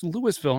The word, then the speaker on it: Louisville: It's spelled Louisville, but it's actually pronounced Louisville, and it's a small town in St. Lawrence County, which is in New York, which is obviously in the United States.